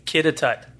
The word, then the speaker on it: Kitatut: So how do you say it again? – It's Kitatut. – Kitatut. Okay. – It's actually a native Inuit word for dancing bear.